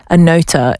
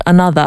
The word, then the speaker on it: Anotr: relax my eyes from another, and I have a confession, and it's kind of embarrassing, but this whole time I thought that that artist was pronounced Anotr. It's spelled A-N-O-T-R, and so I just thought you spell it, you say it how you spell it, but I listened to an interview the other day and I realised, oh, it's actually pronounced another, and that makes so much sense.